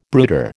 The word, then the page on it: brooder: American English